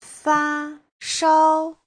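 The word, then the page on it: fashao: fa1shao1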